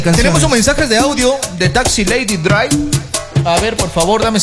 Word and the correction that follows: Taxi Lady Driver: Pronuncia bien, por favor. Taxi Lady Driver.